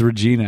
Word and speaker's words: Regina: It's Regina, actually.